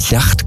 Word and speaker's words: Yacht: Und die Experten werden sagen, ja, Yacht würde doch Englisch aber J ausgesprochen. Stimmt auch, aber ich finde, Yacht klingt besser.